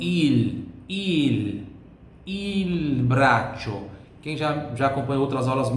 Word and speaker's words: braccio: Por que que é braccio? CI tem som de TCHI. Eu vou escrever aqui, ó. CI tem som de TCHI. Braccio.